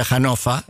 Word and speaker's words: Hannover: Comer había bien, un restaurante en se escribe Hannover, se pronuncia Hannover en alemán.